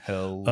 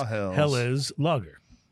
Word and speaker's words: Helles: And so I actually looked up how to pronounce Helles in the context Helles. – Of a lager, and it is Helles lager. Helles.